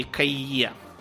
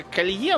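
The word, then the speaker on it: Caillié: Сделав правильные выводы, э, следующим туда отправился француз по фамилии Кайе. У нас почему-то везде пишется Колье, но он именно Кайе.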